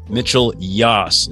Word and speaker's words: Mitchell Yass: All right, Dr. Mitchell Yass.